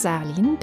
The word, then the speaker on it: Salin: Tobias Salin, bitte die Betonung bei Salin, nicht auf das A, O.